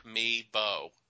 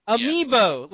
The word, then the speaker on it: Amiibo: Amiibo. – Amiibo, like amoeba, but amiibos. Yes.